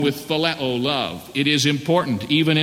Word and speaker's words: phileo: Then there is a third word, which we pronounce in the Greek language by the word phileo, spelled out, it's P-H-I-L-E-O, phileo.